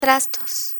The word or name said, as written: trastos